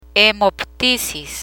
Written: hemoptisis

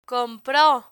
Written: compro

